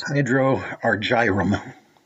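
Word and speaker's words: Hydrargyrum: The name also comes from, how do you pronounce that, Hydrargyros? Hydrargyros? – Hydroargyrum. It means liquid silver in Greek.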